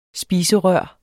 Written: spiserør

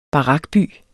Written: barakby

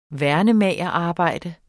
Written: værnemagerarbejde

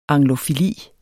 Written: anglofili

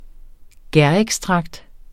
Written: gærekstrakt

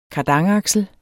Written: kardanaksel

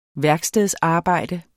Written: værkstedsarbejde

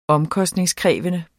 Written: omkostningskrævende

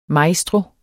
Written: MAESTROER

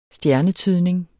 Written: stjernetydning

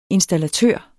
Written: installatør

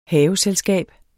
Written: HAVESELSKAB

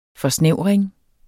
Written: forsnævring

